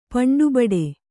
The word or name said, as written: ಪಂಟುಬಡಿ